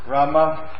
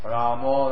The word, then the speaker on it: Rama: In Hindi, it's uh, Krishna, Rama, Rama. But in Bengali, they, they, they pronounce uh, long E, Ra, and then the shorter they say Ma, Ramo. That's how you hear the word Hare Krishna, Hare Krishna.